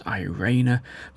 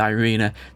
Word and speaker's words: Irina: Now let's talk about Irina, or it's really supposed to be pronounced Irena, but we're going to go with Irina.